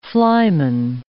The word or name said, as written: flyman